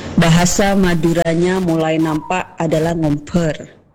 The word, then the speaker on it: ngombar: Kalau dari tulisan NGOMBAR seperti biasa ngombar. Betul. Ya teman berdua. Tapi kalau dalam pengucapan di bahasa Madura itu ada hanya ngomper.